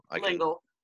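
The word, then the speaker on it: L'Engle: It's Ellingol or Lingol? – Lingol, at least from the videos.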